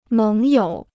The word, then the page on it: 盟友: méng yǒu